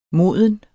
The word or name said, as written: moden